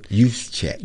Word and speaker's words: Juszczyk: 49ers fullback Kyle Juszczyk. I think that's how you pronounce it. – Juszczyk.